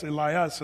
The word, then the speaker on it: Elias: Now, by the way, have you noticed some of your Bibles say Elias? Wow, that's not Elijah. It is. It's just the Greek pronunciation of the Old Testament Hebrew, Elijah. It's Elias in the Greek.